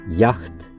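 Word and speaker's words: jaght: この単語をオランダ語で発音すると、ヤフト。途中に H の発音が入っており、正式にはまだ私たちの知るヨットという名前ではありません。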